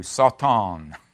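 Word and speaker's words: Satan (שָׂטָן): Satan, or I think more correctly pronounced in Hebrew, Satan, almost like an S-A-W.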